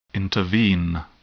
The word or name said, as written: intervene